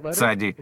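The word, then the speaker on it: цади (צדי): Звук «ц» похож в ней на тот, что в слове «пицца». – Скажите «пицца», «ца», «цади». Вот как нужно произносить эту букву.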